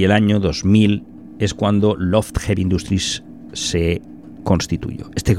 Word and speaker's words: L0pht: Este segundo libro se centra en las intrusiones en las redes informáticas realizadas por desconocidos o grupos de hackers de renombre, como Loft, escrito, o sea, L0PHT, pronunciado Loft, pero escrito en lenguaje LIT, que fueron un conocido grupo de hackers con sede en Boston y fundadores de Loft Heavy Industries.